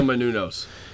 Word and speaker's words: Menounos: Maria Mamumos. – However you pronounce her last name. – Maria Poopsaymunos. – Maria Menounos.